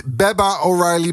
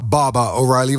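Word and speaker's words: Baba O'Riley: Het is niet Baba O'Reilly, maar Baba O'Reilly, want het zijn Engelsen.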